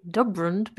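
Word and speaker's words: DUB RND: I say Dubrund because if you go and look on Melbourne Victory's social media, they have stylized it to be D-U-B and then R-N-D.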